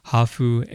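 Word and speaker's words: hafu (ハーフ): Actually, in Japan, we call them hafu, which is half, but with Japanese pronunciation, hafu,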